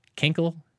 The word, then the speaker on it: Kinkle: Kinkle, kinkle. Now, I will pronounce it the way they would in Kentucky. This is a Kentucky. Kinkle, kinkle. – You got a P? – Kinkle, kinkle? – Yeah, K-I-N-K-L-E.